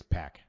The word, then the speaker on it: Pac: Six Pac. Always remember that. That's how you pronounce it. Six Pac.